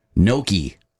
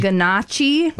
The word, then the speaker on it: gnocchi: Gnocchi. – Gnocchi? – Like the little potato pastas. Gnocchi. – Oh. – It looks like ganache. – Ganache. – But it's pronounced gnocchi or gnocchi or gnocchi.